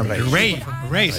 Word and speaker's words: Raye: Ray, se escribe Raye.